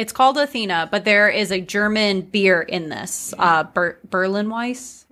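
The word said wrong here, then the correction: Berliner Weisse: Yes, Berliner Weiss.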